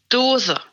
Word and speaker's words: Dose: Hej, sa jag ditt efternamn rätt nu? Eller hur ska vi uttala det? – Ja, Dose.